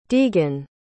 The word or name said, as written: deegan